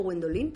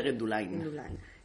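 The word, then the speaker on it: Guenduláin: Estamos en un palacio del siglo XVI, que yo siempre lo digo mal, digo el Palacio Gwendoline, por la canción de Julio Iglesias, pero ¿cómo es, cómo es el palacio? – El palacio de Gendulain.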